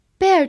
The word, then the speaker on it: bear: bear，b e a r，bear，其中 e a r 字母组合读作 air。所以整个这个词读作 bear。 bear 这个词它有很多的含义，这里我们挑选三个最常见的意思。